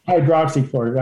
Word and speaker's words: hydroxychloroquine: hydroxychloroquine. – Hydroxychloroquine. I always leave out a syllable.